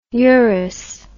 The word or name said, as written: Uris